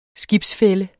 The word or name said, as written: skibsfælle